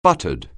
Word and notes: buttered: anglais

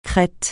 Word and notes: Crète: francais

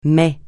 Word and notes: mais: francais

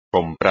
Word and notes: comprar: espagnol

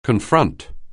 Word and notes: confront: anglais

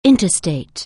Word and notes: interstate: anglais

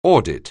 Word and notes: audit: anglais

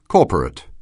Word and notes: corporate: anglais

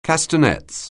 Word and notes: castanets: anglais

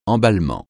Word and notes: emballement: francais

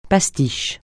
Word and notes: pastiche: francais

